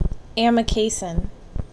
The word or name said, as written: Amikacin